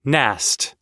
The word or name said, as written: nast